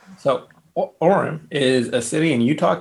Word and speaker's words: Orem: Number one, I might not pronounce this correctly, so correct me if you know how to say this city properly. Orem, Utah? O-R-E-M, Orem, Utah? – Orem. Welcome, Orem.